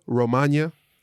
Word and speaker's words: Romagna: We'll talk a little bit of news today, and we'll get into the Emilia Romagna. You got to say it like lasagna.